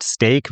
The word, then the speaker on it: stake: Aber darum geht es hier natürlich nicht, sondern es geht um das englische Wort Steak, was leider genauso ausgesprochen wird, aber anders geschrieben wird, nämlich S-T-A-K-E.